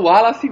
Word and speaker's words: Wallace: É, mas na Bahia se fala o Wallace mesmo. – É verdade, as sílabas bem tônicas, né? – É verdade.